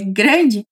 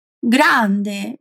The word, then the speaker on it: grande: Já o contrário, para indicar que algo é grande, olha só que diferente do português. Dizemos grande. Na verdade, é idêntico, né? Só a pronúncia que muda um pouquinho, porque o A é bem aberto.